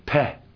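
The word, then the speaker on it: Peh (פ): Peh.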